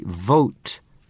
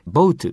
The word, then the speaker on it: vote: 投票という名詞と投票するという動詞に使う VOTE、 vote という単語をぜひ間違えずに発音したい。で、これは発音が重要ですよね。つまり、日本語の ABCD の B で vote って言っちゃうと沈んじゃうやつですからね。ということで、 V-O-T-E、 vote です。